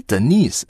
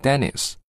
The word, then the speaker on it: Denise: 因为 Denise 啊，是一个在欧美国家非常常用的女生的英文名字。不过请你注意好这个名字的发音，不管你读的是 British accent 英音，还是 American accent 美音，这个词都要读成 Denise Denise，千万不要说成啊 Dennis。